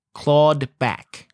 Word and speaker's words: clawed: C-L-A-W, claw. Clawed back.